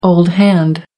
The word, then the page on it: old hand: American English